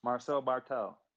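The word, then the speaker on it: Marcel Barthel: Fabian Archer and Marcel Bartel, if I said it right.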